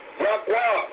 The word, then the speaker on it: Yahweh: English, Lord God, the Metatron, YHWH, pronounce it. Yahweh. Oh, so Yahweh.